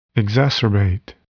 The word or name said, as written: exacerbate